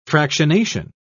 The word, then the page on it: fractionation: British English